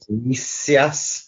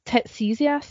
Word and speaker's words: Tiresias: Tiresias, T I R E S I A S. – I thought you said Tetesias there.